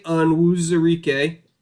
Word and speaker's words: Onwuzurike: I think Levi Onwuzurike was a great pick. – That actually sounds like you actually pronounced that correctly.